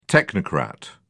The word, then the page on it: technocrat: British English